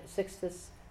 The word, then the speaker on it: Sixtus: New Pope Sexton, I think, is that how you say it? Sixtus the Fourth.